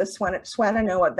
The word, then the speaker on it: Swannanoa: So you're now the minister for Unitarian Universalist Congregation of the Swannanoa Valley.